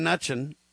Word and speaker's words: Mnuchin: Is it Stephen Mnuchin, Kurt, or Mnuchin? – It's kind of, I think it's almost kind of like the Munchkin men, you know what I mean? That's how you say it.